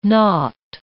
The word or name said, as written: not